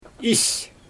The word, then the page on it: is: Xyzyl